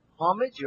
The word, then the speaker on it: homage: There is an H, but is it pronounced homage? – Homage. H, homage.